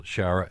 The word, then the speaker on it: Sciarra: Moore, Daniel Scherer. Am I pronouncing that right? – Yes. – S-C-I-A-R-R-A.